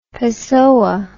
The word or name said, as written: pessoa